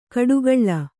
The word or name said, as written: ಕಡುಗಳ್ಳ